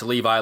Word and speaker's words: Levi: And I was told it's Levi like the jeans.